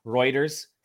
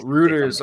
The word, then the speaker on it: Reuters: Actually, I think it's pronounced Reuters. Um, not like steroid or no, but uh, Reuters.